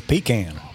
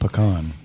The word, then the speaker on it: pecan: Pecan. – Pecan. – That's how it's pronounced down here in the South.